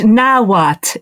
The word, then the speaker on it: Nahuatl: is it Nahuatl? How do I pronounce it? – The Aztec language, Nahuatl, it's pronounced.